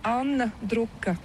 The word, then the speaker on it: Anna Druka: Oui, en letton, les voyelles sont un peu plus profondes et l'accent est toujours sur la première syllabe. C'est la grande différence avec la langue française. Ça fait une difficulté. Mais voilà, mais sinon, je m'appelle Anne Druka. – Anne. – C'est plus profond et c'est Anne.